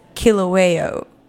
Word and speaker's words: Kilauea: So Hawaii's, how do you say this? Kilauea. – Yeah, that sounds right.